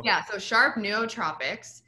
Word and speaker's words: nootropics: I always butcher how to pronounce this, so bear with me, but nootropics. – Okay. – Did I say it right? – I think so, yeah.